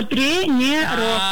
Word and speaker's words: тренеров: Алло, тренеров. На «е» первое.